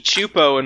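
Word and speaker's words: Csupo: Just need more of that Klasky Chupo in my life. – Is that how you say it? I always said Klasky Kasupo. – It's Chupo. It's Gabor Chupo is one of the founders.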